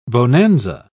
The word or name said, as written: bonanza